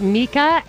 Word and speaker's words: Mika: I just got a tweet from Resist We Much, who said I mispronounced Mika Brzezinski's name, and it's Mika,